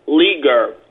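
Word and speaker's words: Leger: Leaguer.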